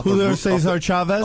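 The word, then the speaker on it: Julio César Chávez: And he hit from shot off Julio Cesar Chavez? – Off the root of a tree? No, but you pronounced that correctly.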